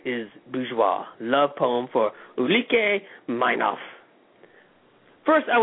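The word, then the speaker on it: Ulrike Meinhof: Ulrika Meinhof. – Ulrika Meinhof.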